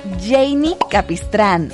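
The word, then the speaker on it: Jeinny Capistrán: Y hablando de nombres, no veo tu nombre en mis redes, arroba Janie Capistrán.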